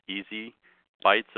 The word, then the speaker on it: ESI Bytes: You can also remember the show and website phonetically if you sound it out. It's Easy Bytes of Information, Easy Bytes of ESI Information.